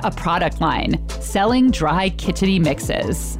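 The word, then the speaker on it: kitchari: It's Kidgeri. It's pronounced in a special way. I have to tilt your tongue to go to the back. It's actually a Hindi word.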